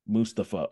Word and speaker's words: Mustapha: Mustapha.